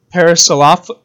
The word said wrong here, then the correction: Parasaurolophus: Yeah, I know that one's hard to pronounce. – Yeah, sorry, I've actually practiced this before the episode, and I just whiffed it there. Parasaurolophus, thank you.